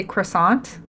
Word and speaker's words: croissant: クロワッサン。So in English we say croissant. – Okay. – I wish I could do the French pronunciation, but it's something like croissant.